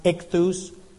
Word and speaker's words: ichthus: It's pronounced ichthus.